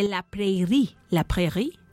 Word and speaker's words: La Prairie: La Prairie, así se pronuncia, del Ultrafam en Luxury Avenue.